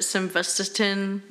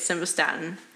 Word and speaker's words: Simvastatin: So Simvastatin, a lot of people will pronounce it Simvastatin, or they don't say it correctly. It's Simvastatin.